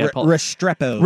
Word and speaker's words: Restrepo: Restrepo.